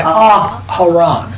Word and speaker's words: Aharon: Otherwise, if it was just Aaron, it would be A-R-O-N. So why A-A? Because that's really Aharon. So it's a three-syllable word, not a two-syllable word. Aharon.